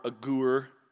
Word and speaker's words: Agur: It's A-G and big letters, G-O-O-R, Agur.